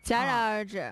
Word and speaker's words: 戛: 但是这个字的这个字的正确读音是戛，戛然而止、